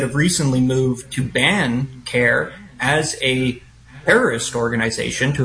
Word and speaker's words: CAIR: CARE is how people pronounce it, C-A-I-R.